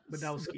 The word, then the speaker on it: Badowski: Studio head Adam Badowski said, I can't say his name, Badowski? – Badowski. Badowski, yeah.